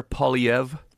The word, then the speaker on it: Poilievre: Pierre Poilievre. – Poilievre. – Sort of a weird R, silent R.